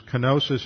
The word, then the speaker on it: kenosis: Kenosis is spelled K-E-N-O-S-I-S. It is from the Greek verb kenao, K-E-N-O-O.